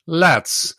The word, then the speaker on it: LADS: And today we're going to be talking about exactly that, LADS. As Matthias just told me, he pronounces the acronym LADS, which stands for Laboratory and Analytical Device Standard.